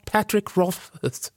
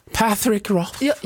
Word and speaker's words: Patrick Rothfuss: Patrick Rothfuss. – Förlåt? – Patrick Rothfuss. – Jag tyckte det var perfekt. – Så hade han uttalat det själv.